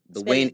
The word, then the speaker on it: Whyne: Find me on Instagram, Wayne Luciano. Wayne is spelled W-H-Y-N-E Luciano. L-U-C-I-A-N-O.